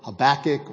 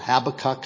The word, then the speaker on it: Habakkuk: Okay, so as obviously I said in the prayer, we're going to be this morning considering the prayer of Habakkuk or Habakkuk, however you choose to pronounce it.